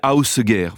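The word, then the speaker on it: Ásgeir: Le nom s'appelle ça s'écrit Asgeir, A-S-G-E-I-R, mais je crois que d'après ce que j'ai compris, on prononce à peu près Ausgeir, voilà. Il s'est fait connaître très jeune, cet Ausgeir,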